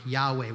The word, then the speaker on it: Yahweh: But we're fairly certain it would have sounded like Yahweh. We spell it Y-A-H-W-E-H.